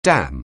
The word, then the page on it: dam: anglais